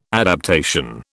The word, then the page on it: adaptation: British English